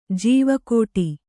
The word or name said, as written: ಜವುಗಿಡು